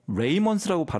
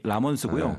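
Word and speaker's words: Ramones: 그래서 76년에 오늘 소개하는 이 보통 이제 R-A-M-O-N-E-S라서 레이먼스라고 발음하시는 분들이 종종 계신데 본래의 이름이 라먼스고요.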